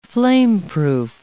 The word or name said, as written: flameproof